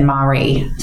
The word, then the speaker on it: Marree: I just listened and the YouTube person said Marree, so I guess I'll say that.